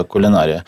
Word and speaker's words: кулінарія: А він перший, перший кухар, який правильно поставив наголос слово кулінарія.